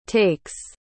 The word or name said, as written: takes